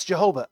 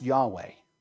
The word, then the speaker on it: Yahweh: Now, some people say, well, it's Jehovah. Actually, that's a misrendering and a misinterpretation from German to English, but it's actually pronounced Yahweh.